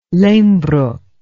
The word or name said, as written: lehmbruck